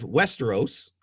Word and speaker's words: Westeros: Tyrion gives a speech about who he thinks should be the new ruler of Westeros.